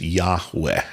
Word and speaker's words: Yahweh: So those four letters, yod, hey, wa, hey, or vav, hey, is what spells out what we pronounce often as Yahweh or Yahweh.